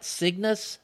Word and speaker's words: Cygnus: Am I pronouncing it right? Cygnus Space Opera? – Yes, exactly.